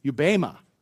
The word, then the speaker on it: bema: The Greek word for judgment used here, you may have heard this before, it's bema. It's spelled B-E-M-A. It's pronounced bema.